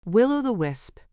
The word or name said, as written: will-o-the wisp